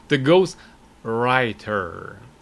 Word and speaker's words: The Ghost Writer: Peux-tu reprononcer le titre en anglais plus tranquillement? – The Ghost... The Ghost Writer.